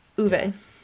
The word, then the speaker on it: Uwe: Is it Uwe or Uwe? – Yeah, something like that. I heard a girl on TikTok say it, and I was like, "Wait, what?" – I'm glad to be corrected because I do not want to say it wrong. So Uwe Rosenberg.